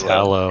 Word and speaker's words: Hallo: Yeah, it's Hallo. – It's Halo with two L's, basically.